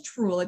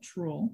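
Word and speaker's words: Trurl: The first one's Truel. It's for our listeners, it's T-R-U-R-L, Truel.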